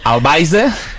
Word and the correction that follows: Alvise Pérez: Eso es correcto. Pérez. Bastante mal pronunciado, pero es correcto. – Al Baize Pérez. – Alvise Pérez.